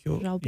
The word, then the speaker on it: Jobim: I can't help J-O-B-I-M. – You. – J-O-B-I-M. – Jobim? – Jobim. – B-I-M. – Jobim. Yeah. Jobim, because it's the Portuguese pronunciation, so it's not a huh.